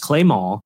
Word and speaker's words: Clément: And my friend TJ, his name is Timothy James LeBlanc, L-E-B-L-A-N-C. And he pronounces it that way, you know, with like the French. And he made me realize, and he explained to me that James Clement probably is James Clément, is how he probably says his name.